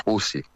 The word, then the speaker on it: Houthi: e sono quelli che conosciamo in italiano come gli Houthi, ma la pronuncia è Houthi,